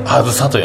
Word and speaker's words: 杏彩: あや彩り色彩の「彩」と書いて「あん」と「あや」と書いて「あずさ」と読むという 17 歳の女の子がオープニングアクトを務めというライブでしたねそれであのああずさちゃん